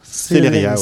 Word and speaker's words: Celeria: Celeria, ouais, comme la vitesse.